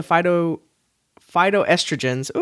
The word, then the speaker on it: phytoestrogens: And the controversy around it is that, as like with other soy products, due to the phytoestrogens.